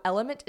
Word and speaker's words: LMNT: So Element is legit the queen of electrolyte replacement. It's spelled like L-M-N-T, by the way.